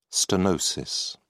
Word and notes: stenosis: British English

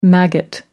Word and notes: maggot: American English

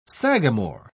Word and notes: sagamore: British English

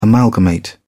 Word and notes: amalgamate: British English

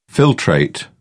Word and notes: filtrate: British English

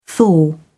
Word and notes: thaw: British English

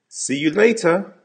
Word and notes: see you later: American English

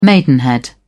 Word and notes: maidenhead: British English

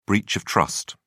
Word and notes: breach of trust: British English

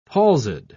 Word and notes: palsied: British English

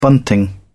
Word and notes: bunting: British English

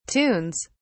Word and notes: tunes: British English

